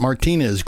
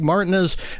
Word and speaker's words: Martinez: Greg Martinez, and that's how he pronounces it, not Martinez. Greg Martinez, a very fine blues soul singer from Louisiana.